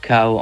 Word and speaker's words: Kau: C'est juste comme on dirait cow en anglais, la vache. Et on les prononce comme ça. Du coup, juste on a changé les lettres, K-A-U.